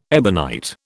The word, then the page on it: ebonite: British English